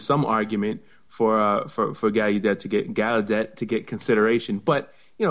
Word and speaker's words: Gallaudet: But we, you know, Gallaudet, you do have a Gallaudet. I'm going to start pronouncing it right, since you used to work there. You must know how to pronounce it. I'll take the U out of it.